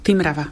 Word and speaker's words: Timrava: No, sú dve skupiny, by som povedala, tých, čo to čítajú meko Timrava a druhý zase s tvrdým, ale ja som sa priklonila k samotnej Božene Slančíkovej, ktorá keď sa hovorilo o jej dovedku k priezvisku Timrava podľa studní, ona chcela, aby sa to vyslovovalo akože tvrdo, Timrava.